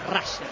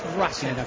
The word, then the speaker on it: Russell: No, pronunciación la real es Russell. – Ok. Russell, sí, muchos le dicen Russell, está perfecto, pero la pronunciación correcta es Russell.